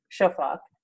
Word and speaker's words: Shafak: Am I pronouncing her name correctly? – Shafak. Yeah.